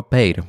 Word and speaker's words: Peyre: Ale, no właśnie, ale, ale w pobliżu tego miasteczka, tego wiaduktu, już zdradziłem co jest, jest miasteczko Peir, pisze się Peire.